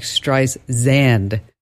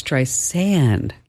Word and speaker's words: Streisand: She was saying it like Streisand. And she's like, no, it's Streisand, like sand on a beach.